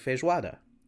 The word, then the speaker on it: feijoada: And I got complimented for my pronunciation of feijoada, which I guessed at. And it was a very proud moment for a nice Brazilian lady to tell me that I had good pronunciation.